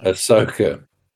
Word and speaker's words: Ahsoka: Ahsoka Tano. – Ahsoka.